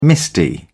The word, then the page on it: misty: British English